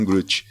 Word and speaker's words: Groot: Que, o, a, assim, não se escreve de forma parecida, mas se pronuncia de forma parecida o nome do Alan Groot.